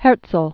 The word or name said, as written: Herzl